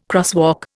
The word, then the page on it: crosswalk: Indian